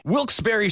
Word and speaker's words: Wilkes-Barre: Wilkes-Barre Scranton's 97.9X.